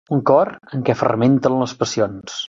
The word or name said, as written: fermenten